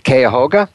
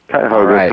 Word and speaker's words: Cuyahoga: And he graduated from, I believe it's pronounced Cuyahoga Community College. – Cuyahoga Community College, that's correct.